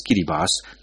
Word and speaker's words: Kiribati: Kiribati, pronounced as Kiribati, located in the Central Pacific Ocean, gained independence on 12 July 1979 from Britain.